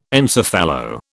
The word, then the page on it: encephalo: British English